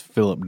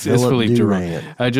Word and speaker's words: Philippe: Yeah, I think it's Philip Durant. – It's Philip. – I'm just joking. It's Philippe Durant. Just so you understand how it's spelled.